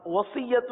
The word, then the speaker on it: وصیت: وصیت کی ہجے الگ کر دیں، واو زبر و، صاد یا زیر صی، صی صی نہیں پڑھیں گے، صی وصی، یا زبر یا وصی، تا پیش تو، وصیت وصیت۔ اب